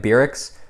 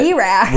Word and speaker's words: Beracks: B-E-R-A-C-K-S. – I'm sure it's supposed to be like Beer-X. – Beer-X. – But like we always say. – B-Rax. – We always say it like it's like the War-X or like the Lorax, I should say.